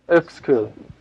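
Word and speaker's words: Uexküll: And so there's this delightful book called A Stroll Through the Worlds of Animals and Men by Jacob von, I believe it's pronounced Exkull, but I'm not sure. Exkull. Thank you.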